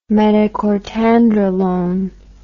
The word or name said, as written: metacortandralone